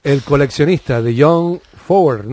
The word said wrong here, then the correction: Fowles: no sé si lo digo bien. John, Fowles.